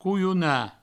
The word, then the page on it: colhonar: couiouna